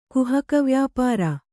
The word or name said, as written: ಕುಹಕವ್ಯಾಪಾರ